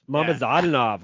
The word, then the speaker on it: Mamadzadanov: Zaur Izayev versus Mukhidin Mamadzadanov. – Mamadzadanov. – Oh, yes. There's an extra syllable on there that I missed.